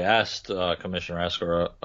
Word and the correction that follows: Oresko: excuse me, wow, I just really butchered his name there, Commissioner Oresko, about this.